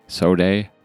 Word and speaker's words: Sodeh: Am I saying that right? S-O-D-E-H. Soda. – Soda.